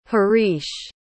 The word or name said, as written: harish